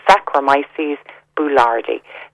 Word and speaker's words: Saccharomyces boulardii: And one of the ones that I find great for bloating, Patricia, is one called Saccharomyces boulardii. So I'll spell it because it's a difficult one. So that's S-A-C-H-R-O-M-Y-C-E-S, Saccharomyces, boulardii, B-O-U-L-A-R-D-I-I.